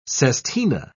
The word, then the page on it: sestina: British English